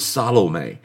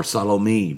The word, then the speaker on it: Salome: And then one of the other four, initially four leaders, was Salome, or Salome, depends on, some people pronounce it both ways, but she was a tetrarch over Jamnia.